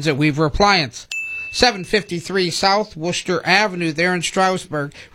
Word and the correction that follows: Strasburg: Right? Strasburg. – We knew what you meant. He was going on the German pronunciation.